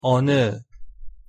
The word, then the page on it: 어느: Korean